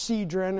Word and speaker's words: Cedron: That brook Kidron there, it's pronounced Cedron in the Greek, Kidron in the Hebrew.